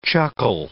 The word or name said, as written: chuckle